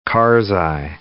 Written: Karzai

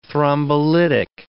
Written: thrombolytic

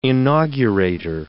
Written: inaugurator

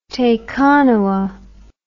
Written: Tekanawa

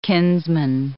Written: kinsman

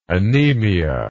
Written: anaemia

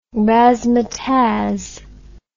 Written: razmataz

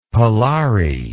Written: parlary

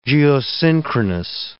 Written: geosynchronous

